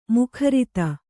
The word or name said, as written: ಮುಖರಿತ